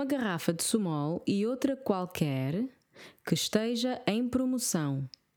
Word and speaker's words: qualquer: Qualquer. Q-U-A, pronunciamos o U. Qua. Q-U-E, não pronunciamos o U.